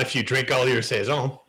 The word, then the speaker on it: Saison: Did I say it right? Saison. – No. Say. – Say. – Zon. – Son. – More of a Z. – Say. – Saison. Zon. – Saison. – There you go.